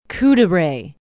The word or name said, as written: couderay